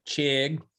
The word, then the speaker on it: Chig: In fact, I said we were in the writer's room and I said, now, how do you pronounce the name of that tight end they drafted again? Teresa Walker said Chig. So that's what I'm going to go with.